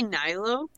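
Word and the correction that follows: Nilou: Nilu, yeah, sorry. Nilo is something that I used to call her, like when it first started off. I didn't know how to pronounce her name, and it's just been ingrained in me, and I know it's wrong, but I still say it like every single time.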